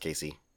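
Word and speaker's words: Cayce: Edgar Casey, and I think it's pronounced Casey, he would go into a sleep-like trance, and his spirit would travel into the spirit realm, okay?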